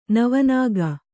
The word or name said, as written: ನವನಾಗ